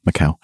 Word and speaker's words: Macau: How do you pronounce that? Macau? Probably. Macau. – Yeah, that's right.